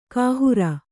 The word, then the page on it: ಕೈಹಾರೆ: kannada; kai hāre